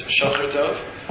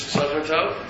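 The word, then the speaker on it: Shocher Tov: Some people insist on mispronouncing it as Shulchan Tov, but whatever, it's Midrash Shulchan Tov, it's Midrash Tehillim.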